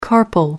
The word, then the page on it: carpel: American English